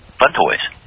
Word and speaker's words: Funtoies: Fun Toys.